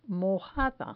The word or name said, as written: mojada